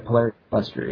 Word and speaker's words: Polarian Cluster: And so I've gone in and I've extracted the Ephemeris site and the I can never say the name right, Polyurn? Polarian Cluster site. – Yeah, Polarian Cluster, yeah.